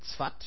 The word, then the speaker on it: Safed: Jahrhundert, also 1800 irgendwas, gab es noch 6700 Juden in den Städten Zwat, spricht man aus Safed, Zwat, Tiberias, Jerusalem und Hebron.